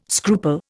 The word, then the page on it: scruple: Indian